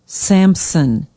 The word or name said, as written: Sampson